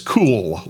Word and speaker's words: CUWL: A lot of people pronounce that as cool licensing.